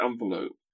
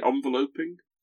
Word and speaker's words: enveloping: How do you pronounce that word, Rob? The you know the thing that you you put a letter inside before you lick it and put a stamp on it. – An envelope. – Right, I'd pronounce it envelope. Isn't it strange mm. when you when you make that into a verb, you don't say enveloping. This fills me with an enveloping joy.